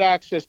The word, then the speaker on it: AXS: And so for people who get Access TV, which is spelled AXS, It's pretty much the last standing music-centric channel on TV.